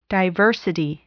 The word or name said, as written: diversity